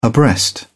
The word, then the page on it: abreast: British English